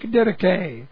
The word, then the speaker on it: didache: Both of them are correct translations of the Greek didache, D-I-D-A-C-H-E, long E, didache.